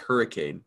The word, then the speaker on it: Hurricane: Its name is spelt like hurricane, but it's called Hurricane.